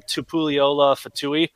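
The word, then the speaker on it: Tupuola-Fetui: That young man's name is, I'm going to butcher it probably, Zion Tupuliola Fatui. It's not bad. I probably did all right on that. – That was actually pretty good.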